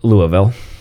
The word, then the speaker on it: Louisville: Near Louisville, that's how you pronounce it, right? No, Louisville.